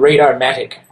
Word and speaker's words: Radar-Matic: Yeah, it's called Radar-Matic. Like automatic, but Radar-Matic.